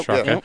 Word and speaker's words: Scirocco: We were winning quite a bit, and this guy shows up in a Scirocco. – Yeah. – I think I'm saying that right. – Yep. Scirocco. Yep.